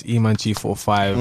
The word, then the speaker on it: E-Man G45: It's E-Man G45. – Mm-hmm. – G solid on my name. The 4-5 is silent.